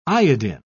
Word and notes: iodin: British English

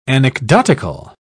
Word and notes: anecdotical: British English

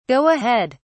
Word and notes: go ahead: British English

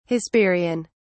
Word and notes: hesperian: British English

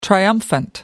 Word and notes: triumphant: American English

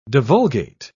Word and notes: divulgate: British English

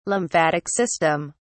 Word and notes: lymphatic system: British English

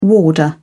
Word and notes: warder: British English

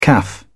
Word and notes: caff: British English

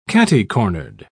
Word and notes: catty-cornered: British English

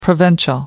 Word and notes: provincial: American English

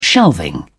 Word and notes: shelving: British English

